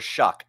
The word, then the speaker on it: Shough: And it's actually pronounced Tyler Shough.